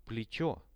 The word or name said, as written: pletcho